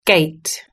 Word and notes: gate: British English

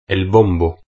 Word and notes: el bombo: Spain Spanish

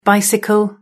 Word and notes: bicycle: British English

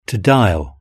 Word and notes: to dial: British English

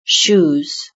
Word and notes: shoes: British English

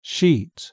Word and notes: sheet: British English

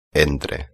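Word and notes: entre: Spain

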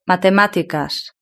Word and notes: matematicas: Spain Spanish

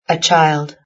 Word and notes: a child: British English

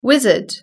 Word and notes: wizard: British English

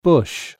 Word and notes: bush: British English

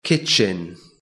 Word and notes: kitchen: British English

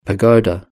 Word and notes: pagoda: British English

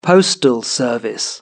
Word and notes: postal service: British English

